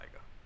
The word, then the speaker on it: Tyga: I'm glad he actually said it right, Tyga. – Yeah, Tyga. Tiger. Who mispronounces that? – I don't know. I mean, I knew people that would like mispronounce it. – Tigger. – I don't know. Tigger.